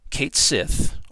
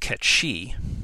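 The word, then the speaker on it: Cait Sith: Cid the airship pilot and former Shinra employee, Kate Sith, or officially pronounced Ket Shi,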